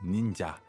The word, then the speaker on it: ninja: But in Japanese, it's pronounced ninja. And in Chinese, it's pronounced 忍者.